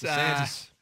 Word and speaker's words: DeSantis: DeSantis has come out and DeSantos. Am I saying it wrong? – DeSantos? Well, I'm saying it with an Oregon accent. – Ron DeSantis. – DeSantis.